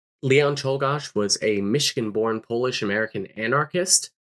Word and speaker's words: Czolgosz: So, next up, we're going to be talking about the assassination of William McKinley by Leon Czolgosz.